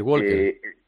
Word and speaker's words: walker: Ah, walker, pues, walker. – Claro, en inglés la L no se produce, no se pronuncia, entonces walker.